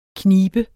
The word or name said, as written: knibe